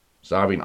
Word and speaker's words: Samhain: Samhain ausgesprochen. S-A-M-H-A-I-N geschrieben.